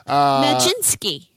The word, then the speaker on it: Magnitsky: So, Magnitsky.